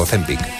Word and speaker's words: Ozempic: O Ozempic, no sé cómo se dice, no sé si lo he dicho bien a la primera, ¿es Ozempic o Ozempic? – Las dos válidas. – Las dos son válidas.